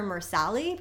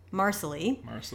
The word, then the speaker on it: Marsali: So Fergus and I always want to call her Marsali because that's how I read it in the book, but it's actually pronounced like it rhymes with parsley. So it's like Marsali.